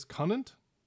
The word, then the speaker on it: Connant: Um, uh, my name is Conant. – Oof. – Conant. Can you spell that for me? Uh, uh, C O N N A N T. Just like it sounds.